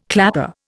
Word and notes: clapper: Indian

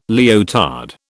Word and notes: leotard: British English

